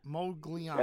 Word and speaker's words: Modigliani: I don't know if I have the pronunciation right, but I got Modigliani. – Modigliani. – Modigliani. – That's how you pronounce it.